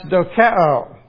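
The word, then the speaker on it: dokeo (δοκέω): It's pronounced dokeo.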